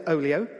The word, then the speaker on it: Olio: So this one could be pronounced anyway, but I'm going to say Olio, O-L-I-O.